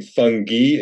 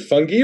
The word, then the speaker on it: fungi: So you're saying fungi, is it fungi or is it fungi? It's fungi? – You can say fungi, fungi, fungi. It's really up to you.